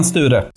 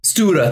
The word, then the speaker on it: Sture: Sten Sture. – Sture, thank you.